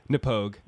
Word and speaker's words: Knappogue: Anyway, okay, so the Irish whiskey that we are having this evening is Napog. Is that how you say it? – Napog. – Napog. – Napog. Yeah. – Okay. It's spelled K-N-A-P-P-O-G-U-E.